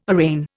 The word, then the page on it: arraign: Indian